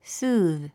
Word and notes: soothe: súːð; スーズ

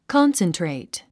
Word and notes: concentrate: kɑ’nsəntrèit; カンセントレイト

